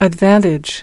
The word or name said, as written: advantage